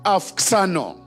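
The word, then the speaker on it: auxano: it is the word afxano. Afxano. Afxano. Now, the spelling of it is A-U-X-A-N-O. A-U-X-A-N-O. But when you pronounce it in Greek, it's afxano.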